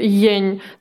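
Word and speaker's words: yan: Тот же соль, ень. Там написано Y, A, N. А читается как ень.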